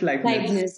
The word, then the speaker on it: Leibniz: Did I pronounce that correctly? Is it Leibniz? – It's Leibniz. – Okay. Yeah. Okay.